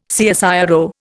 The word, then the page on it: CSIRO: Indian